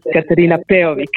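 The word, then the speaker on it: Katarina Peović: I have Twitter and Facebook and Instagram channels, and you can find me under the name Katarina Peovic, which is in English better pronounced as Katarina Peovic.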